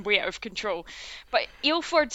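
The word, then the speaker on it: Ilfort: It was Ilford, right? – Yeah. – Ilford, yeah. Because that wasn't how I was going to pronounce it when I first saw how it was spelled.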